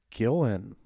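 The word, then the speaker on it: Gillan: Is it Gillen or Gillian? – I keep hearing it pronounced as Gillen.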